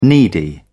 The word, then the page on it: needy: British English